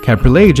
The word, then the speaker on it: Cabriolet: Cabriolet